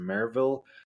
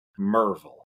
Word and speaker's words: Maryville: And just of tomorrow will be 4 weeks ago, we pulled into Maryville, Tennessee. It looks like it should be pronounced Maryville, but I was informed day one, day two, day three, and following that it's Merville.